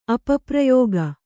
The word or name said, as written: ಅಪಪ್ರಯೋಗ